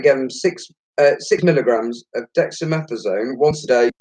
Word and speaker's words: dexamethasone: There's a new drug called, and I'm going to put this out to however I say this wrong, it's called dexamethasone. Is that right, Jay? – You're right there, mate.